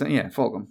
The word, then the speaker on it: Fulgham: Now, is it Fulgham? Is that how you pronounce that? I've seen. – Yeah. – I've heard people pronounce that different ways. – Fulgham. – To me, it's Fulgham.